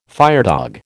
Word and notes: firedog: American English

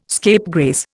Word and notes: scapegrace: Indian

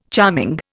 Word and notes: charming: Indian

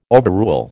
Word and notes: overrule: American English

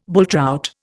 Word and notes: bulltrout: Indian

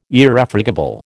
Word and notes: Irrefragable: American English

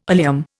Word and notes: oleum: Indian

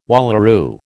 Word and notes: wallaroo: American English

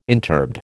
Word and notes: interbed: American English

